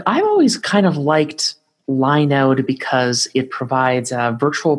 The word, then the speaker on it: Linode: I think you're supposed to pronounce it Linode and not Linode, because if it gets too close to Linux, it might run afoul of some legal thing. So they probably call it Linode. It sounds nicer as Linode anyways.